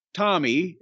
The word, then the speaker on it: Tomi: But Kevin and his wife, Tommy, I get T-O-M-I, Tommy. It's a female anglicization of Tommy.